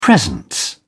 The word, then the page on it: presence: British English